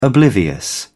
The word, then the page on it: oblivious: British English